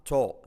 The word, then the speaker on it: tall: I am a tall. Letra clave L casi no se pronuncia.